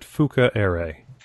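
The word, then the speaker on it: Fukaeri: The narrator in the audiobook pronounced it Fukairi.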